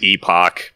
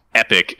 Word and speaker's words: Epoch: epic. – That's how it's pronounced. – Is it? – Yep. – I always pronounce it epoch because, like, when I epoch is actually a term used in like computer science.